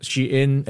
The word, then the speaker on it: Shein: It's pronounced Shein and it's spelled S-H-E-I-N.